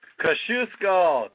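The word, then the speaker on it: Kościuszko: Kosciuszko, am I saying that right? – Kosciuszko, Tadeusz Kosciuszko. – Tadeusz Kosciuszko, okay, all right.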